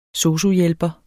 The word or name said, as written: SOSU-hjælper